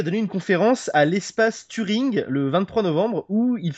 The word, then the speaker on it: Turing: Turing.